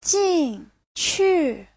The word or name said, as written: jin4qu4